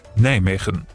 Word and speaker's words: Nijmegen: Richtig ausgesprochen, die Niederländer sagen so. – Nijmegen.